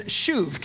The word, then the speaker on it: shuv: And that word, because you love your Hebrew words, I hope, is shuv. Can you say shuv? Shuv. Okay, shuv. S-H-U-V is how you could spell it in English.